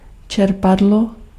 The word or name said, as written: pompe